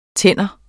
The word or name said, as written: TANDEN